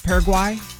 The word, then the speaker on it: Paraguay: Paraguay. – Did I say that right? – Paraguay.